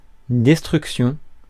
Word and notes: destruction: American English

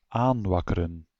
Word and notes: inciter: France; [ɛ̃.si.te]

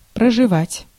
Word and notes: spend: American English; /spɛnd/

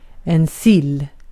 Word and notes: sill: /sɪl/

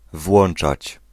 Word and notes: ouvrir: France (Paris); /u.vʁiʁ/

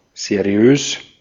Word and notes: seriös: /zeʁiˈøːs/

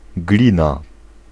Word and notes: clay: /ˈkleɪ/

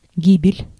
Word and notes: гибель: /ˈɡʲi.bʲɪlʲ/

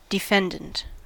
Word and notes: defendant: /dɪ.ˈfɛn.dənt/

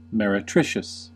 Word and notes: meretricious: /ˌmɛrɪˈtrɪʃəs/; /ˌmɛrəˈtrɪʃəs/; English

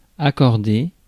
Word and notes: accordé: [a.kɔʁ.de]; France

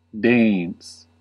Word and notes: tooth: /tuːθ/

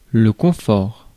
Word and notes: confort: [kɔ̃.fɔʁ]; France

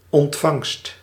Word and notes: ontvangst: /ɔnt.vaŋst/

